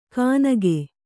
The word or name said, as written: ಕಾನಗೆ